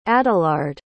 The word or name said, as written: adalard